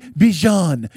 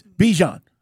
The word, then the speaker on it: Bijan: And by the way, we found out how to say his name last night, or at least how he likes to say it, right? Because at first it was, we were saying Bijan. – Yeah. – Now it's Bijan. All right, which one is it? Bijan. That's what it is.